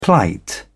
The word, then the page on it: plight: British English